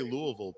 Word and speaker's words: Louisville: Nicaragua, but you say Louisville perfectly. – Yeah, right? – I get fired, I think, if I don't say it right. – Like, no, for real. I have two best friends that were born in Louisville, and like they taught me young. Like, that's how you say it.